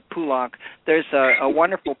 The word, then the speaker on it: Poulenc: Some people say Poulenc, and some people say Poulenc. And since you said Poulenc, I will continue to do so also.